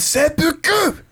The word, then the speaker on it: seppuku: It's seppuku. That's how you say it.